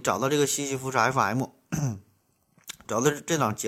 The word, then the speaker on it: 西西弗斯: 然后啊，对西西弗斯啊，这发音不准，西是东西南北的西哈，西西东西南北的西，福呢是，呃，佛教的佛，没有达林儿啊，福啊，西西弗斯是呃斯大林的斯啊，斯，F M 就是英文字母 F M 啊，收音机的 F M 嘛，西西弗斯 F M 啊。